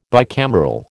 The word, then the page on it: bicameral: American English